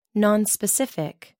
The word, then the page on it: non specific: American English